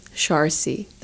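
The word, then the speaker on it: Saoirse: Oh, Cioris. – Charcy, that's how you say her name.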